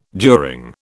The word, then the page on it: during: British English